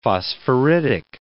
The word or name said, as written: phosphoritic